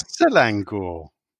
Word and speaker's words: Selangor: You called it Sangalore, I believe. – Yeah. – Typical of you to mispronounce what was spelt out probably Sangalore. In front of you. – Yeah. – Because it was Selangor.